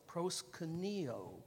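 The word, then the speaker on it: proskuneo (προσκυνέω): Accent on that O, proskuneo.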